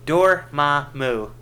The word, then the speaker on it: Dormammu: I think we're definitely going to see Dormandu. – Come on, you guys, Dormammu.